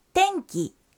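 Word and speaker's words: টেনকি (天気): এই কাঞ্জির উচ্চারণ হল টেনকি টেনকি।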